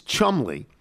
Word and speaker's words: Cholmondeley: Cholmondeley is Chumley.